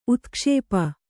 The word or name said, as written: ಉತ್ಕ್ಷೇಪ